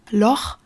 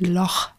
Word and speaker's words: Loch: Loch tatsächlich mit einem sehr kehligen Ch ausgesprochen, so wie in Bach, ist ein schottisch-gälisches Wort und wird im Englischen natürlich nicht so ausgesprochen. Also die Engländer würden eher vom Loch Ness sprechen, aber wenn wir von Schotten hören, dann sagen die tatsächlich Loch Ness.